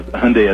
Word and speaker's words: Hyundai: So it was Sunday as Hyundai as in Sunday,